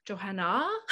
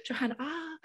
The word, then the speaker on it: Johannah: The only tricky thing about my name is it has a sneaky little silent H on the end of it. So Johannah, Johannah Newmarch. So Johannah. Newmarch.